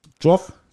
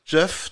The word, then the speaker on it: Geoff: Wie spricht man diesen Namen hier aus? – Geoff. So wie Geoffrey. – Geoff.